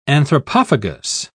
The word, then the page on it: anthropophagous: British English